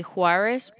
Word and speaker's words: Juarez: The first name is going to be Daniel, and then the last name is going to be Juarez Perez.